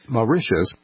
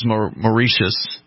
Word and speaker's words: Mauritius: Mauritius. – And it was Mauritius, basically.